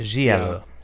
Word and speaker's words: giallo: I go with the soft G. I call it giallo. – Giallo. Okay.